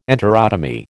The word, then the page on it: enterotomy: American English